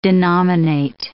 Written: denominate

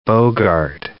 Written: Bogart

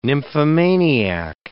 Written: nymphomaniac

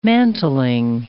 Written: mantling

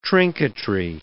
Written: trinketry

